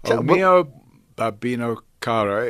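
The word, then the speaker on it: O mio babbino caro: O Mio Babbino, right? – Mio Babbino Caro.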